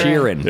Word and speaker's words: Sheeran: Sheeran.